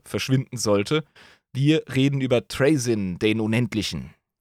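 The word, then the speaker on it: Trazyn: Ja, und verzeiht mir die englische Aussprache, ich bleib gerne bei den äh, Englischen, weil es einfacher ist. Äh, viele sprechen den Trasin aus oder sonst wie.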